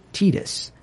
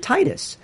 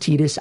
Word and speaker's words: Tidus: and the guy pronounced it Tidus. So it stuck. So there's some guy out there responsible for it, Tidus, when everybody else reads it Titus. But it is Tidus.